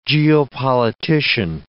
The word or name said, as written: geopolitician